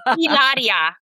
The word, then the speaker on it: Hilaria: Hilaria. – Hilaria. – Hilaria. H is silent.